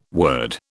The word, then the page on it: word: British English